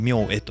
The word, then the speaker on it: 明恵: 明恵明るい恵みと書いて明恵と読